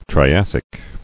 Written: Triassic

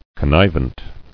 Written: Connivent

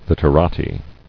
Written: Literati

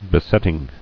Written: Besetting

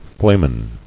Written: Flamen

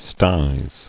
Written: Sties

